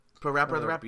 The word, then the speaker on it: PaRappa the Rapper: Parappa the Rapper.